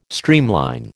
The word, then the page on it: streamline: American English